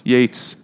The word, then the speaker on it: Yeats: How do you say fucking his name? – Yeats.